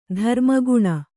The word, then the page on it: ಧರ್ಮಗುಣ: dharma guṇa